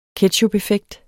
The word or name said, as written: ketchupeffekt